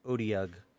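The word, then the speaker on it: otyugh: It's O-T-Y-U-G-H. I'm not sure how to pronounce it. I had to get online and look it up. It's O-T-U-G. Odiug.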